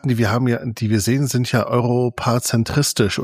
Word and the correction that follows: eurozentrisch: oder wie das ausgesprochen wird. – Eurozentrisch, ja.